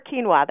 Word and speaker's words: quinoa: Except for quinoa. That's my one exception. I can't pronounce it, but it's not pronounced the way it's spelled, but I still buy it.